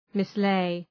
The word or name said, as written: mislay